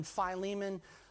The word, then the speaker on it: Philemon: You might say, well, why are you saying it Philemon and then Philemon? Look, Philemon is really the right way to say it because the basis of Philemon's name, Philemon, is phileo, which means brotherly love.